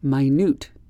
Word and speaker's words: minute: D-I-M-I-N-U-T-I-V-E, diminutive. How about this one? Minute. Minute, M-I-N-U-T-E. It's spelled the same as minute, but it means very small.